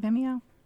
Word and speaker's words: Vimeo: Is that how you say it? Vimeo? – Mm-hmm. – Vimeo. – Yes.